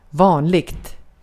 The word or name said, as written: vanligt